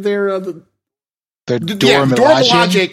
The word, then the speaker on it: Dora Milaje: Dora Milaje